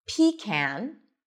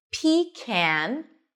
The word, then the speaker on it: pecan: You can either pronounce it as pecan, pecan, where the stress is on the first syllable, pecan, or pecan, pe, short I, pe, pe, unstressed, con, with an oz and father.